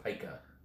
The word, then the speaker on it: pica: But picarism is... – I think it's pronounced pica.